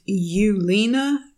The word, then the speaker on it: Ewelina: And then the last thing I am stocking is something called the Cozy Pullover, and it's by a designer named Yulina Murak. And the designer name was familiar to me because it's spelled E W E L I N A. So you, as in the female sheep.